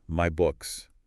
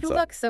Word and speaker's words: MyBookXXX: MyBookXXX is pronounced MyBooks. Kind of a long sound at the end. – MyBooks, okay.